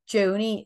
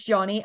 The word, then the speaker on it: Joni: Joni is pronounced like pony with a J. Loads of people think it's Johnny, and by the way, how would you think that Joni, J-O-N-I, is Johnny? It's Joni.